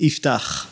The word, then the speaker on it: Iftah: Well, I'll tell you, it's an Israeli name. It has like a chet. So it's hard for Canadian. So I take it easy on them, but you basically pronounce it Iftah.